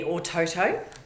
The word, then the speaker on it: Ōtautau: Born in Invercargill and grew up in a small town called Otautau, which the correct pronunciation now would be Ōtautau.